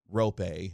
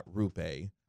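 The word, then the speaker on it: Roope: Why don't you correct your man off the mic, laugh out loud, is pronounced Rope, not Rupe.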